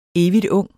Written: evigtung

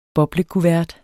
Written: boblekuvert

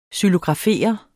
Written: XYLOGRAFERE

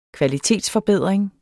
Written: kvalitetsforbedring

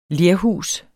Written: lerhus

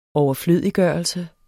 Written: overflødiggørelse

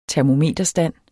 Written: termometerstand

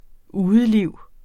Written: udeliv

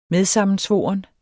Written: medsammensvoren

